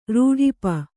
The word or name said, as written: ರೂಢಿಪ